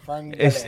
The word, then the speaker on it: fleur-de-lis: It's like fleur-de-lis. – It's a F-L-E-U-R.